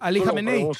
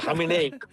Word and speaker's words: Jamenei: Entonces, Trump está furioso y hoy le contestó a Ali Jamenei. – Tú lo pronuncias mejor que yo, a Jamenei.